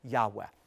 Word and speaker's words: Yahweh: But most Semitic scholars today, most scholars of the ancient Hebrew language believe that the way that God's personal sacred name should be pronounced, once again, is Abrethi Yahweh.